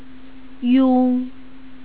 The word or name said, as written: yong